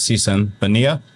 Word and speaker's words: Sisan Baniya: Diz, Rabindra, and we have a special guest, Sisan, Baniya.